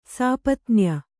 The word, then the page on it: ಸಾಪತ್ನ್ಯ: sāpatnya